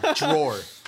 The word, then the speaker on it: drawer: You do not say drawer. – One syllable. Drawer.